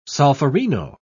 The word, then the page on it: solferino: British English